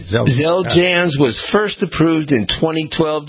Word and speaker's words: Xeljanz: I'm going to challenge you to pronounce, this is a drug, it's X-E-L-J-A-N-Z, okay.